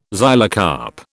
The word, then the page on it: xylocarp: British English